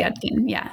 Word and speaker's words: Yudkin: And I did say that correctly, right? Yudkin? – Yes, okay. It's Yudkin.